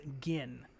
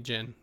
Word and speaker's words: Gin: G-I-N. – Yes, I noticed it was G-I-N. Mm-hmm. It makes sense it wouldn't be Jin, but in my mind, that's how I pronounced it the whole time.